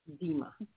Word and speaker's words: Dema: It's Dema. – Dema. – Yeah. Just like Tina.